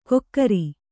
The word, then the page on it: ಕೊಕ್ಕರಿ: kannada; kokkari